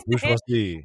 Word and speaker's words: bourgeoisie: Bourgeoisie, yeah.